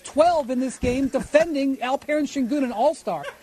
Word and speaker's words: Alperen: Oh, Windy doesn't know how to say Alperen. Like, Alperen. – You gotta, if you're going to roast him, you got to say his name correctly.